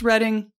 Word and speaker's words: Reading: Spelled Reading, pronounced Reading.